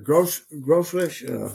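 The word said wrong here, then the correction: Grolsch: Grosh, Dad.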